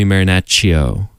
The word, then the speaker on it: Marinaccio: But anyways, I learned many years afterwards that, in fact, her name was, or she says her name should be pronounced, Julie Marinaccio, because of the two C's, make a C-H sound, which really shook me to my core.